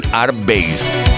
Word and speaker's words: Airbase: Betty Miranda sería un claro ejemplo de ello, una producción de Airbase, mejor dicho, ¿eh? lo pronuncio bien, Airbase.